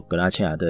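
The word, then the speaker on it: Garnatxa: 那因为西班牙的话会有刚刚讲的格纳恰 G A R N A C H A 啊，然后还有这个另外一个也念作格纳恰的原因是因为是加泰隆尼亚的发音啊，叫它的拼法就会是 G A R N A T X A 啊，那刚刚还有念了一个阿里冈多。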